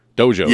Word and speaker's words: Dojo: There's apparel, a Dragonplex JoJo now, right? Dojo, yes. – Excuse me. – Yeah. – I think I mispronounced that.